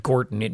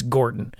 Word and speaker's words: Gordon: I pronounce it Gorton. It's Gorton, G-O-R-D-O-N.